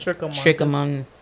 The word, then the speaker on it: Trichomonas: Trichomonas.